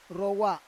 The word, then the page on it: rowa: Roon